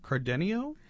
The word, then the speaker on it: Cardenio: The history of, let me make sure I have it pronounced correctly, Cardenio? – Yes, The History of Cardenio.